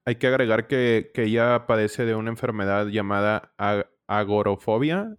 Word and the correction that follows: agorafobia: Ah, ¿Cómo se dice? – Agorafobia. – Agorafobia, sí, perdón.